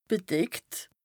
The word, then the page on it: bedeckt: Bas Rhin; Strasbourg